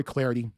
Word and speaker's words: Clardy: At Troy Clarity, my last name is spelled C-L-A-R-D-Y.